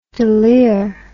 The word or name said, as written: delir